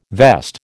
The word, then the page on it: vest: American English